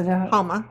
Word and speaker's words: Houma: There's a town, Houma. – Houma, Louisiana? – Is that pronounced? – Houma.